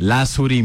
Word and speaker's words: Lazuri: La Suri Music.